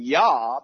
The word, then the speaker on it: Yah: I mean, no one argues, generally speaking, about the short poetic prefix being Yah, because everyone knows that Hallelujah is the same in all languages, right?